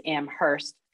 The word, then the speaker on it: Amherst: Yeah, and I do like your pronunciation. It is Amherst.